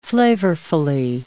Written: flavorfully

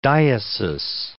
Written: diesis

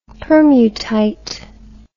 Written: permutite

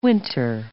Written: winter

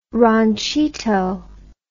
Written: ranchito

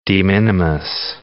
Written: de minimis

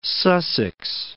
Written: West Sussex

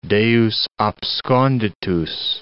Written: Deus absconditus

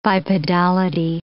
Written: bipedality

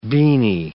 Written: beanie

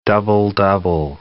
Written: double-double

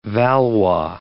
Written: Valois

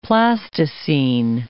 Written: plasticine

